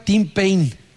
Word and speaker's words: Tim Payne: El jugador se llama Tim Payne. Tim, T-I-M, y Payne, se escribe P-A-Y-N-E.